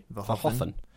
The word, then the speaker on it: Verhoeven: And he's also a big Paul Verhoeven fan. I just learned recently that's how you pronounce Paul Verhoeven's name. – Is that true? – Verhoeven.